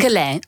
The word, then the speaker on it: Quellin: En werkzaam op het Stedelijk Lyceum Quellin, zeg ik het goed? – Quellin. Zijn Frans. Ja. – Op zijn Frans.